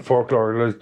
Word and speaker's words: folklore: It was like American folklore, because America has got... – You don't pronounce a K, it's folklore.